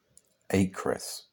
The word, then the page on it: Acrise: English